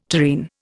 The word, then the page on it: tureen: Indian